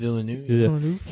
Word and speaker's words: Villeneuve: So I feel like, I think Denis Villeneuve, how do you pronounce it? Villeneuve? – Villeneuve.